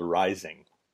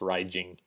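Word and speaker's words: Raizing: So this game was developed by a company called Rising, which apparently is pronounced Raijing in Japan.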